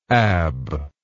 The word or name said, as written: abb